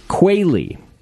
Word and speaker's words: Quealy: You do. Quealy. – Yeah, like the bird.